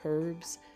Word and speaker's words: herb: Column 3 also has the silent H at the beginning. Let's say these words. Air, herb, and sometimes people will say herbs, but most of the time the word is pronounced herb. Homage, honest, honor, and hour. They begin with H, but the H is silent.